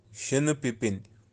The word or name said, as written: sheeni-pipin